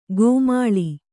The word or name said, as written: ಗೋಮಾಳಿ